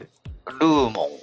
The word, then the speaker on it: Lumon: ルモンって読むの？ L-U-M-O-N って。 – ルーモン。